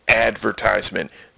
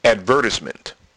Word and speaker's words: advertisement: advertisement. Now, over in England, they don't pronounce it advertisement. They pronounce it advertisement. They don't emphasize the ad. They emphasize the vert.